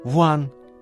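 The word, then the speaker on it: 王: Ван произносится во втором тоне, переводится как «князь», «царь», «король».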